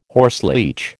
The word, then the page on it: horseleech: American English